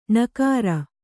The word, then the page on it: ಣಕಾರ: ṇakāra